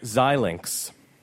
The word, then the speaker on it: Xilinx: Mary, Xilinx. I'll spell that for you. – Okay. – X-I-L-I-N-X. – Tech company.